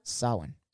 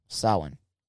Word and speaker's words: Samhain: How do you pronounce that word? – Samhain. – Samhain? – Samhain. – Samhain. Cool. I didn't know how to pronounce it.